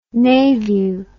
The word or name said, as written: navew